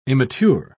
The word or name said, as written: immature